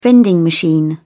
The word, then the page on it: vending machine: British English